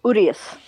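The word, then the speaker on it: Urías: Urias. – Yeah, yep. Just tonight. So it's not Urias, it's Urias.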